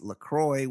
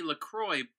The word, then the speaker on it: LaCroix: How do you pronounce that word? L-A space capital C-R-O-I-X. – I say LaCroix, but I have heard people say La Croix. – It can't be La Croix.